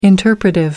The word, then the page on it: interpretive: American English